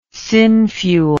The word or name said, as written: synfuel